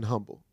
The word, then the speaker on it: humble: I want to let y'all know that you do pronounce the H in humble. Amen. Because, you know, I know it's a little discrepancy with that. The word herb has an H. You don't pronounce it. The word humble, you do.